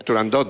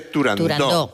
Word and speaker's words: Turandot: Turandot no, se dice Turandot, que es... – Como lo pronunciaba Puccini y como lo pronunciaba Toscanini.